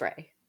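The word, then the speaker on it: Raey: Ray, it's R-A-E-Y. – I think that's Ray. – Ray, okay. – I would say Ray.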